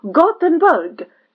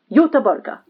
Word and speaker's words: Göteborg: Nay, Gothenburg. So we pronounce it Göteborg.